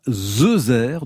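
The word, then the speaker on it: zeuzère: Et vous l'avez bien prononcé, pas de cheveux sur la langue. Zezère s'écrit bien avec 2 Z.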